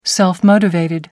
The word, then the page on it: self motivated: American English